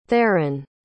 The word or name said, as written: tharin